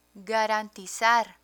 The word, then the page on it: garantizar: Spanish